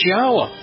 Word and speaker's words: Yahweh: And you examine the other words that are so common in Hebrew, like Torah, and it becomes obvious how to pronounce his name. It's Yahweh.